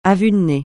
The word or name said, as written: à vue de nez